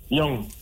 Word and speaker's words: Young: Young, Y-O-U-N-G.